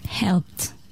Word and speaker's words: helped: Help se termine par un P. Dans ces cas-là, ou ces terminaisons-là, on va prononcer le ED, T. Prononcer le ED, T. Alors, help, qui se termine par T, sera prononcé helped.